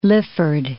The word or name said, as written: Lifford